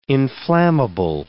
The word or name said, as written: inflammable